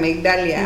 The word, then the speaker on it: Migdalia: Porque mis clientes pues no no pronuncian migdalia.